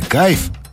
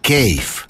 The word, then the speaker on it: кейф: В слове «кайф» английского языка нет даже близко. И вообще правильно говорить «кейф».